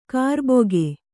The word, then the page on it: ಕರ್ಬಕ್ಕಿ: karbakki